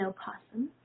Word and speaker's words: opossum: And so people think that they're going to get rabies from an opossum, but they're not. The O can be silent, and that's how I pronounce it.